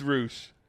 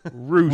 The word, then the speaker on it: Rush: Is it really Roosh? – It absolutely is Roosh. You don't think I'm going to think it's Roosh? He's from AAA. – Douche. – It's Roosh. What was that? – Douche. – Close. Roosh. – Roosh. Rhymes with? – Douche. – That's exactly right.